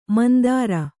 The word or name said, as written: ಮಂದರ